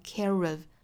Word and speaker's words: care of: 我们要知道这里面不管英音还是美音哈，都是应该读成 care of。